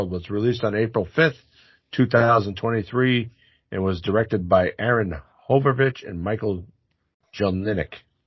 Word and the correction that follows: Horvath: Butcher that one for Horvath. Wow. Horvath, yeah, I'm sorry.